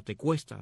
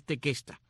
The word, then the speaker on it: Tequesta: Otros indios de esta área son los llamados tequesta o tecuesta, de acuerdo como lo mencionas, lo pronuncias al inglés o el español, pero es tequesta con Q, T-E-Q-U-E-S-T-A, los tequesta,